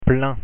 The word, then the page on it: plein: French